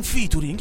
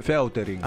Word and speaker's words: featuring: Ah, vedi, io voglio fare un featuring, io voglio featuring scattone. Lo voglio fare, lo voglio fare, lo voglio fare. Non si dice fe- featuring. Featuring. Si dice fe- Feutering. Ah, Feutering.